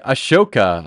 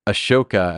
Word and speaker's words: Ahsoka: Okay, Ahsoka.